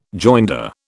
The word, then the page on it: joinder: British English